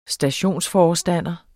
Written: stationsforstander

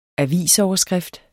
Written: avisoverskrift

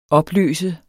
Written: opløse

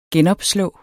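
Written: GENOPSLÅ

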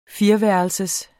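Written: fireværelsers

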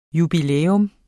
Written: jubilæum

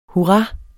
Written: hurra

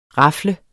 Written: rafle

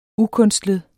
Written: ukunstlet

